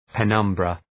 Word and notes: penumbra: pı’nʌmbrə

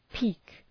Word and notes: peek: pi:k

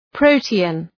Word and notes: protean: ‘prəʋtıən

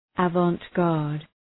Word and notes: avant-garde: ə,vɒnt’gɑ:rd

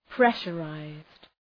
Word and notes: pressurized: ‘preʃə,raızd

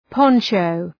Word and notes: poncho: ‘pɒntʃəʋ

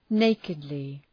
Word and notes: nakedly: ‘neıkıdlı

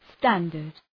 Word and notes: standard: ‘stændərd

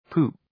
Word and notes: poop: pu:p

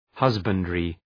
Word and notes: husbandry: ‘hʌzbəndrı

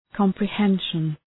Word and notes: comprehension: {,kɒmprı’henʃən}

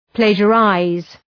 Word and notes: plagiarize: ‘pleıdʒə,raız

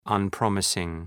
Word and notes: unpromising: ʌn’prɒmısıŋ

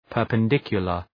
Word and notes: perpendicular: ,pɜ:rpən’dıkjʋlər

